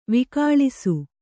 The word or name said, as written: ವಿಕಳಿಸು